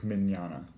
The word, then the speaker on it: Mignogna: So, do you know the Vic Mignogna? I can never say his fucking name right. Vic Mignogna. Mignogna. – He's dead to me. I don't need to learn his name. – Mignogna. Mignogna? – Vic Mignogna.